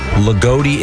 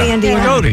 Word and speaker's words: Loogootee: L-O-O-G-O-O-T-E-E. – Lagodi? – Lagodi, Indiana. – Lagodi, yeah. – Sure. – Wow, Lagodi. That's a great Lagodi name. It sounds like a camp. – I wonder what they are. The Lagodi Warriors or something? – Lagodi?